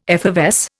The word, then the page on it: effervesce: Indian